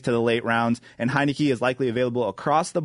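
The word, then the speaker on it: Heinicke: Is Tyler Heineke? – That's how you say it.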